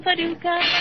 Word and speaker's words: Paducah: If you want to, you can rhyme it with bazooka. But you can't poo-poo Paducah. That's another name for paradise. Paducah, Paducah. Just a funny little city in Kentucky. But to me, it rhymes with lucky.